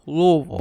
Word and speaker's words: Louisville: Probably Louisville. – Yes, that's exactly how people who aren't from here say it. And we say it like you have a box of marbles in your mouth. Louisville. Louisville.